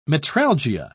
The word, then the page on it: metralgia: British English